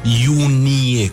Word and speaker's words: iunie: 2, 9, 29 și 30 iunie. Iunie. Iunie cu N. Vă rog foarte mult să pronunțați corect, Iunie.